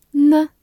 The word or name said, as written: 呢